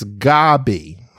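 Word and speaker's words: Gaby: Okay, well, Robin, you spelled my name wrong. I only have one B. It's G-A-B-Y. I understand. That seems to be typical, but it's Gabby. So Gabby is two Bs, but that's okay.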